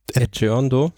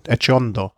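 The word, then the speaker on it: Etxeondo: Also, und auch schon lange mit dem auf den spanischen Ex, wie spricht man aus? Du kannst es besser als ich. – Echeondo. – Echeondo. Ja.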